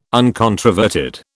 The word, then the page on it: uncontroverted: British English